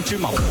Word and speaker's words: 芝麻糊: 芝麻糊，糊面第四声。